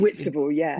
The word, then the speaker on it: Whitstable: What are the, you live in, is it Whitstable? Am I saying that right? – Whitstable, Whitstable, thank you.